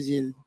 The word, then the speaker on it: Zild: Did I say that correctly? Zild? – Yes po, si Zild.